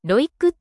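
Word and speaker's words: ROIC: ロイク、ロイックって読むんですよね。 – そうです、ロイック。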